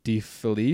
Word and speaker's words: DeFelice: Like you have Kevin Hazard, author of 1,000 Naked Strangers, Jim DeFelice, is how you say that? – Yep, exactly.